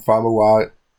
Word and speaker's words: Famuyiwa: Famuyiwa.